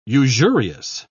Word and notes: usurious: British English

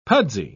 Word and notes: pudsy: British English

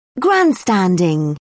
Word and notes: grandstanding: British English

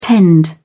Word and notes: tend: British English

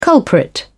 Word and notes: culprit: British English

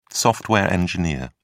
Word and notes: software engineer: British English

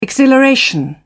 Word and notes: exhilaration: British English

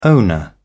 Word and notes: owner: British English

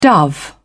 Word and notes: dove: British English

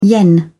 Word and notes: yen: British English

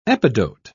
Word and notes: epidote: British English

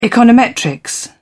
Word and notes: econometrics: British English

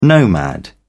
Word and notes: nomad: British English

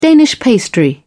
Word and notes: danish pastry: British English

